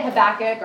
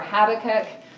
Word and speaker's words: Habakkuk: So we know that Habakkuk, or Habakkuk, I've heard it pronounced both ways,